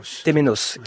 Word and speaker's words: Temenos: Dites-moi, reprenez-moi peut-être si je la prononce mal, le Temenu. Temenos, qui authentifie.